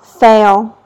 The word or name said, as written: fail